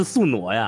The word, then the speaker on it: 宿傩: 然后我今天一查，哦，原来是宿傩呀！虽然这个字儿认识，但是读音还是读反了。